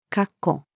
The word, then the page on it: 括弧: female